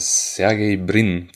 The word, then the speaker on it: Sergey Brin: Ja, ich meine, es hat ja auch letztes Jahr oder vorletztes Jahr der Sergey Brin, das ist der einer der Eigentümer von Google.